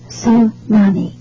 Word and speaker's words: tsunami: It's pronounced tsunami, even though it begins with a T. It took me quite a while to figure out how to pronounce it.